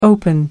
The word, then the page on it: open up: American English